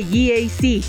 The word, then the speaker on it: GAC: No se dice GAC, se dice GAC, nuevamente GAC, y se maneja mejor de lo que se pronuncia.